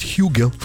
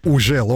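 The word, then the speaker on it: HUGEL: Трек называется «Ужел», а кто-то говорит «Хюгел». – Как мы его только не называли в свое время. «Ужел», «Хюгел», да? «Хюгель». – Самое правильное произношение – это «Ужел». Он француз.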